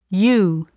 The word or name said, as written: ewe